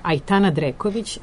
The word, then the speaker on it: Aitana Dreković: E sada, dok smo se dogovarali, smišljali smo i kako se ovo izgovara. Aitana Dreković, a knjiga poezije se zove Angara, i ona je dobila nagradu Milutin Bojić za 2017. godinu, ali tako? – Tako je.